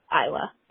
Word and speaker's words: Isla: Um, because this is really unimportant, but I'm like 99% positive her name is Isla Fisher, not Isla Fisher. Um, and I say that because I've looked it up before and there are YouTube videos of her pronouncing her name as Isla.